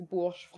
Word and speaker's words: Bourges: So, I mean, Emily, you had all of these correct. It's Bourges, France.